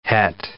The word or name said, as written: het